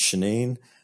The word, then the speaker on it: Shannin: Uh, it looks like Shannon, but it's pronounced Shanine.